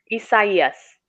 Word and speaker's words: Isaias: Like, Isaias. – Isaias. – Yes, that's how they say we should have pronounced it.